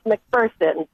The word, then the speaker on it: McPherson: And no, we try to tell people there's no fear in McPherson, but it's pronounced McPherson, Kansas. So there you go.